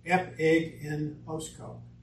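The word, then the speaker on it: epiginosko: It's E-P-I, epi, plus ginosko, G-I-N-O-S-K-O, and it's pronounced epignosko. Epignosko.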